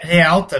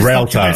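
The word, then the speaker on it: Réalta: Mas é Realta é mesmo que fala, porque às vezes... Realta, Realta.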